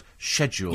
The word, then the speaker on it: schedule: I was delighted to hear you correct Alan on his mispronunciation of the word schedule. – Yes, schedule. – I hear it all the time. Schedule. It's schedule. If it was schedule, it'd be SK.